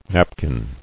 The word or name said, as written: napkin